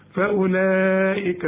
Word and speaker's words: فأولئك: فاولئك الواو بقى اللي عليها الخمسه ديت حرف عله يكتب ولا ينطق، ما تقولش فاولئك فاولئك، يعني ثلاث حروف عله الالف والواو والياء، تلاقي عليهم الدوران الخمسه ده اكتب الحرف لكن ما تنطقوش،